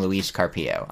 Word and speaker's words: Luis Carpio: Dear hosts, I have a question somewhat inspired by Luis Carpio I gotta get better about saying Luis Carpio. I'm gonna say his name a lot on the podcast. I have said his name a lot on the podcast. I remember to accent the uh, second syllable in both cases.